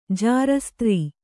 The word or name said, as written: ಜಾರಸ್ತ್ರೀ